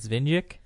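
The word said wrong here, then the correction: Višnjić: Okay, Vishnich.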